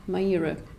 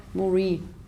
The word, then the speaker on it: Maire: und jeder ruft sie so, wie man es schreibt, Maire. In Wahrheit, in Irland wird dieser Name Marie ausgesprochen. Und ja, es blieb bei Maire.